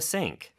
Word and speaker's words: sink: sink하고 싱크대. s하고 시옷의 발음에 차이가 있어요. 그래서 sink, s 발음은 좀 더 입 앞에서 나는 소리라는 거 요것도 기억해 주세요.